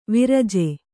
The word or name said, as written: ವಿರಜ